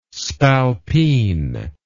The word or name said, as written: spalpeen